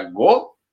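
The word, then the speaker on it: go (ご): Porque uh, em japonês o número 5 se pronuncia go e o número 9 se pronuncia kyu.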